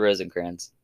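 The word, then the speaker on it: Rosenkranz: How do you say her name? Rosencrantz or Rosencrantz? – Rosencrantz.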